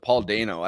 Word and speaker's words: Dano: All right, so this is about Michelle Williams and Paul Dano talking about which Steven Spielberg pronounces it Paul Dano. I don't know if that's like how it's either or. – Well, Dano, I think it was Dano.